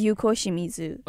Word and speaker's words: Yuko Shimizu: like um, Yuko Shimizu. I'm trying not to screw up their names here. Is that how you pronounce it? – Yes, Yuko Shimizu.